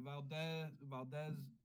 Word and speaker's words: Valdes: But that's why it's pronounced differently. Like Valdes is also like a Spain version of that last name.